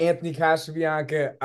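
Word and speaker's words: Anthony Casabianca: A little bit about Anthony Casabiancaa. – I'm pronouncing that right? – Yeah, perfectly.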